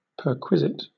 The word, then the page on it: perquisite: British English